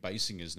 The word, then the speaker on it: Basinger: It's the only reason that I know Kim Basinger's name is pronounced Basinger. – Yeah, because of that, because Alec Baldwin, yeah. – Because Homer calls her Kim Basinger, and she goes, it's Basinger!